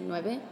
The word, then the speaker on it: Nueve: So my graffiti name is Nine, but I spell it in Spanish, so you pronounce it Nueve, N-U-E-V-E.